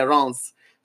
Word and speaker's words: Rennes: I don't even know how you pronounce that. – Rennes. – Yeah, Rennes.